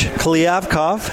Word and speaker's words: Kliavkoff: New Pac-12 commissioner, George Kliavkoff. How did I do? I've been practicing. – That's pretty good.